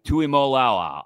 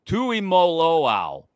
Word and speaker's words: Tuimoloau: Number one, edge rusher from Ohio State, JT Tuimoloau. I butchered his name. Tuimoloau.